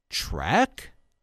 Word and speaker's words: Trek: Are they going to make that same claim now when it's Trek, which rhymes with wreck, which leads to Mary Burke?